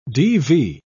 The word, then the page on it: DV: British English